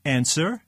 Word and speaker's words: answer: Answer. – W. – W and Neil.